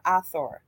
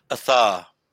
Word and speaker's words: Aether: Is it Aether or Athor? – Athar. – Athar. See, I didn't say it correctly.